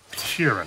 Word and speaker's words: Sheeran: Frank Sheeran. Am I saying that right? – Yeah, you said it right.